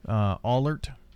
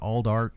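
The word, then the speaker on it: Allardt: They all of them mispronounced the little town up in Cumberland County or Fentress County. – Oh, really? – Allert. – Mm-hmm. – And he called it Aldart and all kinds of different things.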